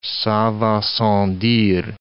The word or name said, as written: ca va sans dire